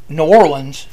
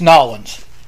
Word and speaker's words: New Orleans: It's not New Orleans. It's Nolens. – Right. – Yes. – So I believe it was, my dear boy, you're doing it wrong. It is Nolens.